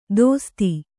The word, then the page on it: ದೋಸ್ತಿ: dōsti